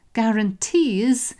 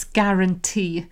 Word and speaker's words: guarantee: Notice that, so I put a little quiz there, quiz question about how do you pronounce guarantee. Lots of people say guarantee, but it's a silent U, it's simply guarantee.